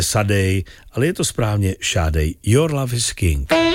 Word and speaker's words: Sade: Známe ji jako Šádej, takhle se to správně vyslovuje, jinak někdo říká třeba Sade.